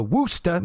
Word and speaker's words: Worcester: The first order of business was to cancel TV tapings for Worcester, Massachusetts. They pronounce it with a woo and not a war, like where I am from.